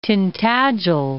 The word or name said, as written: Tintagel Head